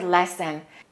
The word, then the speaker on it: lessen: Let's look at the word lessen. To lessen is pronounced exactly the same as the noun lesson, an English lesson. So that E and that O are pronounced the same because they're both reduced vowels.